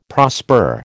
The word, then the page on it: prosper: American English